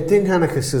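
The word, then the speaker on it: Dinnseanchas: And this was the existing terminology for names and places went back centuries in Ireland, and it was the Dinn Chánachas. Am I pronouncing that right? – Yeah. Dinn Chánachas is the study, the lore of place names in the ancient Irish tradition.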